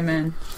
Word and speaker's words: Mehn: Glenn Men? – I'd say Men. – M-E-H-N, for those of you Googling it. Three very different Baker Streets, three very different Sherlocks, three extraordinary new cases.